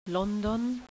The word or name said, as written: London